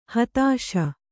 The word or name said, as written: ಹತ್ತಿಸು